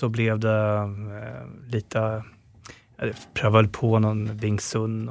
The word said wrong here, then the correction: Wing Chun: och jag vet inte ens hur det uttalas, Wing Chun.